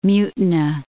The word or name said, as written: Mutina